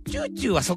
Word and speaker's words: 美しい: チューチューはそっからですね。 – そうですね。はい。美しく。 – 美しい、美しいと書いて、この番組もチューチューと読みます。